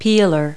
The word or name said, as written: peeler